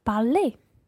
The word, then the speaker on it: parlé: It sounds the same as the infinitive parler, but it's spelt e-acute at the end. So to say I have spoken, you would say j'ai parlé.